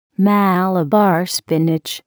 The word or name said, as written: Malabar spinach